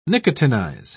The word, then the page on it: nicotinize: British English